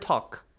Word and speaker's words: talk: Can you spell the word talk? – T-A-L-K. – Ah, L is silent there.